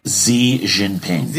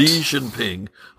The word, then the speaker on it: Xi Jinping: Xi Jinping. – President. – That's correct. – Xi Jinping.